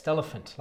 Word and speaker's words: Stelephant: I say it's Stelephant, like elephant, but with Stella at the start.